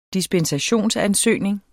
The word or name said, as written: dispensationsansøgning